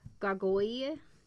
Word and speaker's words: gargouille: Relates how he delivered the country around Rouen from a monster called a gargouille. It's French. I did look it up. Gargoyle. – Okay. – That's how it is. – It's like a strigoi. – Pretty much. It's spelled G-A-R-G-O-U-I-L-L-E. – Gargoyle. – But it's like gargoyle is how it's spelled.